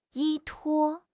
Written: 依托

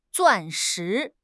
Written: 钻石

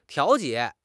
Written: 调节

